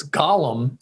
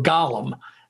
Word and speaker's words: Gollum: And in Lord of the Rings, it's Gollum, spelled differently, pronounced differently.